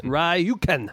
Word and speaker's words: Rjukan: It's R-J-U-K-A-N. How would I say that? – Rjukan. – Rjukan.